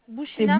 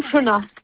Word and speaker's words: Bouchena: Bouchina. C'est Bouchina. – Bouchina. Pardon. Cela s'écrit B-O-U-C-H-E-N-A.